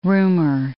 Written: rumour

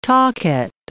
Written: tacet

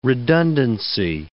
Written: redundancy

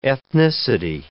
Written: ethnicity

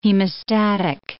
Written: hemostatic

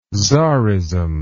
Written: tzarism

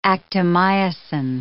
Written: actomyosin

